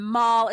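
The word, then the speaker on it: mal: And the reason I know it's Mall is because Darth Maul is bad in Star Wars. And Mall is how you say, I'm bad.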